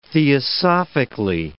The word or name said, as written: theosophically